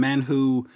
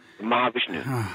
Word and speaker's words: Mahavishnu: You're out with, of course, all the different bands as a drummer and the orchestra that I can never pronounce the first name, Manhu. – Mahavishnu.